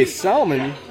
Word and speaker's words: salmon: There is a salmon. It's salmon, right? – Salmon. – Sorry, I like to pronounce the L.